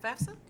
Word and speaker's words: FAFSA: You're the first person I've ever encountered that said it right. – Fafsa? – Yeah, there we go.